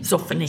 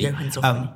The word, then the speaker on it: Johann Zoffany: Zoffany. – Zoffany. – Johann Zoffany.